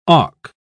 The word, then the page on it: auk: British English